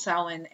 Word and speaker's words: Samhain: I learned that the Glenn Danzig band Samhain should be technically pronounced Samhain, and